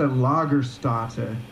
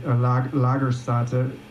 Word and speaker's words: Lagerstätte: It's a Lagerstätte. It's a German word. I think I'm saying it right. Lagerstätte. Correct us, please.